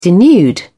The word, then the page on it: denude: British English